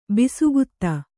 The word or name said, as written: ಬಸುಗುತ್ತ